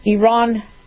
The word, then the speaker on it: Iran: Iran is asking that we pronounce it correctly.